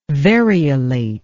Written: variolate

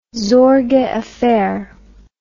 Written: sorge affair

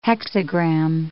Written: hexagram

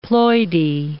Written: ploidy

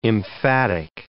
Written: emphatic

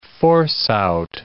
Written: force-out

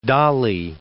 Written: Dali

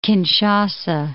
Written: Kinshasa

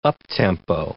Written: up-tempo

